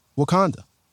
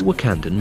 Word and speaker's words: Wakanda: to the point where he actually mispronounced Wakanda and said Wakanda.